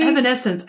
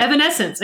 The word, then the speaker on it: Evanescence: Evanescence. Ugh. – She said it correctly. See, Evanescence. I can't see. You just said it right. – Evanescence.